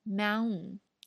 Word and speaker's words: Mountain: 同样，Mountain Mountain，还有这个《二零一一太空漫游》也是一个非常有名的短小说、短篇小说。